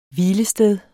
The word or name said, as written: Hvilesteder